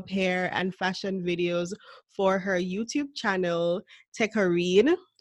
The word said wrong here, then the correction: Takara: Is that how it's pronounced? Takara Renee. Oh, okay. My apologies.